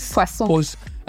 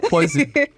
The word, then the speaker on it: poisson: They said in French, the word fish is what is poisson. – I used to pronounce that poison. poison.